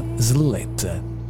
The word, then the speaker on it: Sletta: Si chiama Sleet, si pronuncia così, si scrive perlomeno così, S-L-E-T-T-A, Sleet, Good Signs.